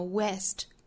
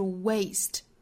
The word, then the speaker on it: waste: We would never waste it If it was money, 首先我们注意 waste 这个词，它是一个双元音的 a 的发音，不要跟另外一个 a 的那个元音哈，就是单元音不要混淆了，不要读成了 west, 那应该是 waste, 注意元音的滑动感，从第一个元音滑动到开口度更小的元音 a, 从 a 到 a, way, Wasted.